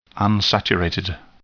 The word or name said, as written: unsaturated